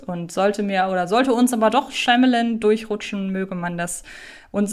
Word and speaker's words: Shyamalan: Ich habe vor einiger Zeit mal ein Interview mit ihm führen dürfen und wurde im Vorfeld dazu angehalten, seinen Namen Shyamalan auszusprechen. Und wer meine absolute Lieblingsserie Community kennt, der erinnert sich ja auch an eine Szene, in der Abed darauf verweist, dass er Shyamalan ausgesprochen wird.